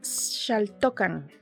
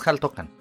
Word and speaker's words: Xaltocan: se llama La Bruja de Shaltokan. Shaltokan. – Ok. – Ah, ok. – Es Jaltokan. – Ah, Jaltokan. Ah, ah, perdón. Es Jaltokan. Xaltocan porque trae acento en la. – Ajá, en la primera.